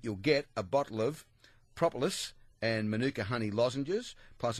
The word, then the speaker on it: propolis: For the last 3 weeks they put different pronunciations up on top of this, and today they put no pronunciations on top of it. So it's propolis.